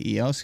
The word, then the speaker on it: EOS: I say EOS because it's the proper way to say it.